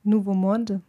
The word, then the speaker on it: Nouveau Monde: Nouveau Monde.